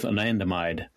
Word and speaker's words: anandamide: There's one in particular compound that I'd like our listeners to understand, and that's anandamide. Am I saying that correctly? – Yes, you are. The bliss molecule. – The bliss molecule. That's why it's so important. So what's going on with anandamide? – Okay.